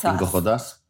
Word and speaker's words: Cinco Jotas: Cinco Jotas. Perfect pronunciation. – Oh, I thought it was called 5J. So thank goodness you're here. – I think it's more, Cinco Jotas is more the way to say it.